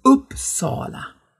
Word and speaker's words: Uppsala: geschlossenes O und deshalb ist es Uppsala. Also eigentlich zwei Betonungen in diesem Ortsnamen.